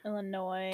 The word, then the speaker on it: Illinois: Illinois. – The S is silent.